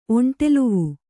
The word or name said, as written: ಒಂಟೆಲುವು